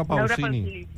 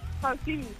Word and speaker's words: Pausini: ¿habéis dicho Laura Pausi? – Pausini. – Ah, vale. O sea, ¿qué pasa? ¿Que las últimas sílabas no gustan o qué? – Laura Pausi.